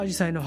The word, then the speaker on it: 紫陽花: なんですけども、これはですね、昔からアジサイの発音はアジサイだったそうで、